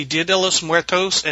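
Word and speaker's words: Día de los Muertos: It's actually Dia de los Muertos, and it celebrated November 1st and November 2nd. – And to add, I knew you guys were pronouncing it wrong, and I just want to be a know-it-all.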